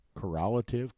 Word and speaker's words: correlative: And as a close correlative to correlative. – You said it right the first time.